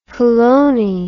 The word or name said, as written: polony